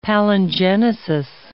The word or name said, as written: palingenesis